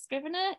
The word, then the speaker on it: Scrivener: Is it Scrivener? I never know how to say it. Scrivener. – Yeah. Scrivener for the W.